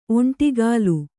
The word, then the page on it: ಒಂಟಿಗಾಲು: kannada